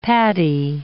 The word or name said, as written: paddy